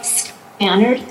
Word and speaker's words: Stannard: Mr. Chairman, members of the committee, my name is Paula Stannard. That's spelled S-T-A-N-N-A-R-D.